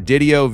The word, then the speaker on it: Didio: Him and I were working on his last name before we started recording, and I think he said it rhymes with video, so Didio, video, video, Didio.